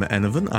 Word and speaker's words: Enevun: Hello, I'm Enovan.